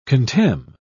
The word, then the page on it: contemn: British English